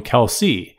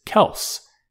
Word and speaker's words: Kelce: And my dad, at some point, when he was working in the steel mills in Cleveland, Ohio, got tired of correcting everyone who was calling him Kelce. Apparently, the correct pronunciation, the standard pronunciation is Kelce, K-E-L-S-S. He didn't say K-E-L, I spelled that.